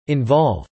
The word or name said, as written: involve